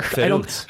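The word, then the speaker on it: Verrückt: Verruckt. I don't think they pronounce it that way in Kansas City. – It's Deutsch. Yeah. It means crazy.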